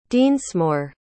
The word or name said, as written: dinsmore